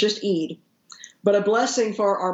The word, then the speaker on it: Eid: It's just pronounced Eid is the name of the, I guess it's a festival or a feast that they have at the end of Ramadan.